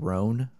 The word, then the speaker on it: Rhone: It's called Rhone, I think it's pronounced. It's R-H-O-N-E.